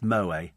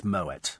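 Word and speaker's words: Moët: And before you write in and say, it's Moet, no, it's not, it's Moet.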